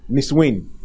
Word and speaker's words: Nissuin: Niswin. Niswin is spelled N-I-S-S-U-I-N. The Niswin. N-I-S-S-U-I-N. Let me spell it again. The last time someone was asking me, what's the spelling once again? It's N-I-S-S-U-I-N.